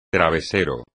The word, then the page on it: travesero: espagnol